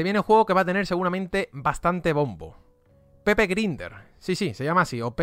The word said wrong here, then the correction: Pepper Grinder: Pepper. Pepper Grinder, ¿vale? Va básicamente mejor pronunciado.